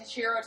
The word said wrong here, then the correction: Katsuhiro: Tomo? Katsuhiro. Katsuhiro.